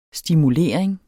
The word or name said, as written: stimulering